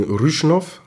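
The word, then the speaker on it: Râșnov: Aber nach den Springen in Rüschnow, habe ich es richtig ausgesprochen? – Jawohl, sehr schön.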